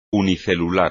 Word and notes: unicelular: espagnol

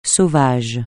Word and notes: sauvage: francais

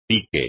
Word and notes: pique: espagnol

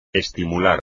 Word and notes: estimular: espagnol

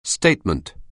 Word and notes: statement: anglais

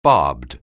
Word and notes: barbed: anglais

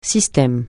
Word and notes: système: francais

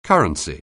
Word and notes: currency: anglais

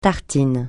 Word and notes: tartine: francais

